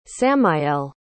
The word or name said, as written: samael